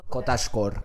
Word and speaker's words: cottagecore: O sea, una cottagore core, ¿cómo se dice eso? – Cottagecore. – Cottagecore, yes.